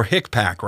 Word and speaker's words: HICPAC: Now, you have some big problems with the Healthcare Infection Control Advisory Committee, or HICPAC, right? Is that how you say it? – Yeah, that's how you say it.